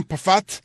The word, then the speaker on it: Pfaadt: Brandon Pafat por el equipo de Arizona. Se pronuncia así, pero se escribe P-F-A-A-D-T. PAFAT.